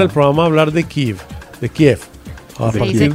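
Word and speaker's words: Kyiv: Usted sabe que hoy hablamos con un experto que trabajó con el Ministerio de Defensa de Ucrania y nos dijo que Kiev se pronuncia así, en ucraniano es Kiev, porque Kiev es la pronunciación rusa y para ellos es ofensivo. – Es bien ofensivo que uno lo pronuncie Kiev.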